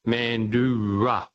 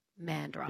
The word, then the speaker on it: Mandurah: It's an indigenous name, and it's pronounced Mandura. – Yeah, Mandra. Mandurah.